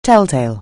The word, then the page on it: telltale: anglais